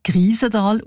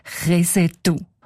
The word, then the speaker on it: Kriesental: Der Ueli Sagesser schreibt, ein älterer ortskundiger Mann habe den Namen allerdings nicht als Kriesental ausgesprochen, sondern als Kresetu.